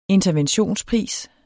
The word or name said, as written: interventionspris